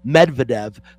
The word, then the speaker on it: Medvedev: Medvedev. Let me get that straight. Say his name properly. It's Medvedev, but